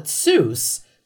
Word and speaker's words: Seuss: Okay, so it's Zeus. – Is correct. It's his mom's maiden name, Seuss or Zeus, if you will. – Uh-huh. – And it was supposed to rhyme with voice.